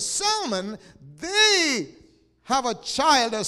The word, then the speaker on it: Salmon: S-A-L-M-O-N. But the L is silent, right? But there's a male name in Israel, S-A-L-M-O-N, where the L is not silent, it's pronounced.